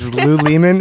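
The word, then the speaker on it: Lew Lehman: You think it's Lou Lehman? – I think it's Lou Lehman. – Lou Lehman. – But it's spelled L-E-W.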